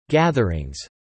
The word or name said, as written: gatherings